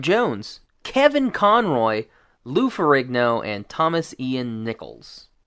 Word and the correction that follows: Nicholas: Nicholas. Sorry, I butchered his name.